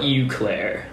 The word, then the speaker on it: Eau Claire: Because the word O starts with an E. – Oh, right. It's spelled E-A-U.